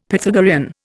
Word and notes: Pythagorean: Indian

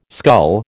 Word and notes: scull: American English

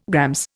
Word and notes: GR: Indian

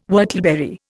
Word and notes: whortleberry: Indian

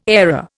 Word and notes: airer: Indian

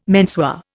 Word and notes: menswear: Indian

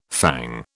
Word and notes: fang: British English

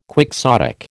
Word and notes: quixotic: American English

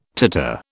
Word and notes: titre: British English

